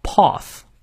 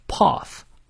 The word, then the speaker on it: path: 注意，our 那个 r 不念，而这个 path 美式发音发 a，但是英式发音的元音念 a path path。